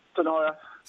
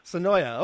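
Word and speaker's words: Senoia: You're on the air, and am I pronouncing Sonoy correctly? – Oh, it's close enough. – Well, don't be not close enough. How do you say it? – Sonoya. – Sonoya. Okay, great.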